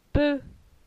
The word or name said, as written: Peu